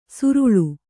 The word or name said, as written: ಸುರುಳು